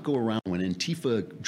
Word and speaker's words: Antifa: this thing with Antifa. Now we got to put the accent on the T. It's on the wrong syllable.